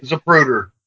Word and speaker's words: Zapruder: I think it's the Zapruder film, I believe, if I'm pronouncing his name right. – Zapruder, that everyone refers to.